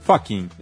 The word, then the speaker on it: Fachin: Você sabe pronunciar direito o nome do novo... É Fachin ou é Fachin? – No italiano, Fachin ou Fachin? – Fachin.